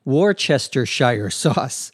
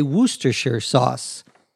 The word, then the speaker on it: Worcestershire: Notice that the pronunciation of this word is not like the spelling. You would think it would be pronounced something like Worcestershire sauce, but it isn't. It is from the town of Worcester in England, and that's how it's pronounced.